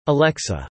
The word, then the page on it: alexa: American English